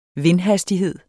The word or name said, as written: vindhastighed